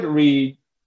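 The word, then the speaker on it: READ: So we call it Reed.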